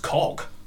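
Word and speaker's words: Kauke: Look, Henrietta K-A-U-K-E. I assume that's pronounced Cock.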